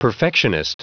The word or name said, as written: perfectionist